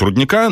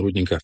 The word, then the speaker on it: рудника: Правильно говорить рудника, но шахтер говорит рудника. – Да, все правильно, рудника.